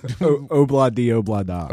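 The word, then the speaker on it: Ob-La-Di, Ob-La-Da: Obla di obla da.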